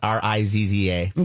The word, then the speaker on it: RZA: RZA, but pronounced RIZZA.